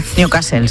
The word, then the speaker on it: Newcastle: Continúa el goteo de focos de la enfermedad de Newcastle en, lo he dicho bien. – Newcastle, sí, sí. – Bueno, medio bien.